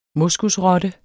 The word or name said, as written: moskusrotte